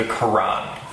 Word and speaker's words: Quran: Quran. And that's, if you want to know how to pronounce that, it's K-O-R-A-N, okay? Quran. It makes it a little easier to pronounce, but it's spelled with a Q.